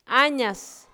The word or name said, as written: Añas